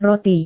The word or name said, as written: roti